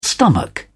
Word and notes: stomach: British English

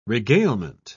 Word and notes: regalement: British English

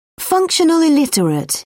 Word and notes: functional illiterate: British English

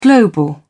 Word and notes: global: British English

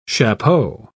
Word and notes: chapeau: British English

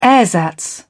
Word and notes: ersatz: British English

